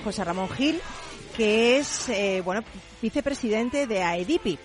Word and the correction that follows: AEDIP: ¿Lo digo bien? AEDIP. – AEDIP.